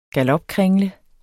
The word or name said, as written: galopkringle